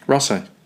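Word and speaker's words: Raasay: Do you say Rasay or Rasay? – Scotland. – Oh, sorry, Scotland. How do you, I was so worried about mispronouncing. – Rasay. – Rasay. – Yeah, two A's. R-A-A. – Yeah. – S-A-Y.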